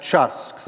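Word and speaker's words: chus (חוס): It's spelled C-H-U-S, chus. Say that word, chus.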